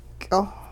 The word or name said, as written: ky'oh